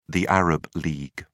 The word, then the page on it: arab league: British English